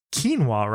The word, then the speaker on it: quinoa: And I used to call it Quanoa, Conoco. I don't know, but it's really not that whatsoever. And so if I don't know, I just act confidently and say things, but it's actually pronounced quinoa, right? – Quinoa, yeah.